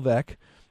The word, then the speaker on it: Veeck: So the book you want to look at is Bill Vec, Baseball's Greatest Maverick. And his name, if you're not familiar with his name, it's spelled V-E-E-C-K, rhymes with wreck. As he titled his autobiography, Vec, as in wreck.